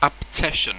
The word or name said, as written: Abtettion